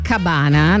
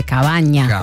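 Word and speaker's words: cabaña: Rama, la nuova si chiama Cabana, noi lo diciamo all'italiana, però è un termine spagnolo, vedo che la pronuncia esatta sarebbe Cavagna.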